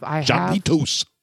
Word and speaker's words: Jarritos: Jarritos.